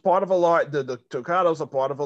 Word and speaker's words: toccata: but to listen to your playing of the toccata.